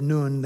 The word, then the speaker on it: Nun: I think the proper pronunciation is Nun there in verse 18.